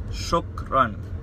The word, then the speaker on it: shukran: If I want to say it to someone, I say shukran, shukran. So, two syllables. The first one is shuk, shuk, shuk. Just imagine you're shuk. So, shuk, and then the second syllable is run, run. It's not run, it's run, run, run. So, shukran, shukran, shukran.